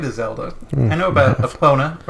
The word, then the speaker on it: Epona: I say Epona because, like, it's E-P-O-N-A, and to me that's like pony. So it's the pone sound, so Epona.